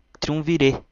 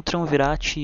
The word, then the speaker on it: Triumvirat: Na verdade, se pronuncia Triunvirê, mas a banda é conhecida como Triunvirate e eu prefiro assim pela analogia com o rato que eles sempre tiveram, né?